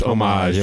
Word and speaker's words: homage: Just put like a weird like droplet in the background just to give us some Hamish or something. Did I say that wrong? – I like the homage. It's homage. Amish is a religion.